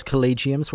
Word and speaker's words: collegium: So they were calling themselves collegiums. What's that term that they're kind of leaning on? So the word collegium, and in plural collegia. – It's a hard G.